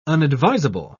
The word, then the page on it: unadvisable: British English